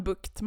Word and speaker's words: Bucht: Men Bukt. Man får tänka liksom att man får bukt med någon.